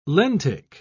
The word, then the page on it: lentic: British English